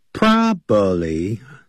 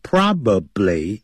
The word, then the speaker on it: probably: He confesses 相当于 he admits， A-D-M-I-T 加个 s，他承认。承认什么事呢？或者整个 that 从句做他的这个宾语。 That he'll probably faint，他很可能会昏倒。 Probably很可能，he'll probably faint. Probably 这样念的不对的，叫 probably 啊，probably 这样念，p r o b a b l y probably 可能会昏倒，faint 不要念成 faint aiding.